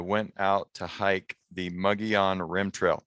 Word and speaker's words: Mogollon: But from what I've been able to figure out, there's five different correct pronunciations of the term Mogollon. So if your grandfather's uncle's cousin grew up in Sholo and he says Mogollon, that's fine. But I'm going to say Mogollon because that's how I saw some Forest Service people on YouTube say it.